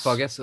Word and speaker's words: Bogus: Sierra Bogus, is that how to pronounce it? It's Sierra Bogus. – Bogus, oops, sorry.